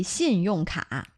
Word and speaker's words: 信用卡: Are you using two syllables there? 信用卡. – No. 卡 is one syllable.